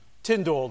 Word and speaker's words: Tyndale: So let's talk about William Tyndale today. Yes, that's how you pronounce his name. Tyndale.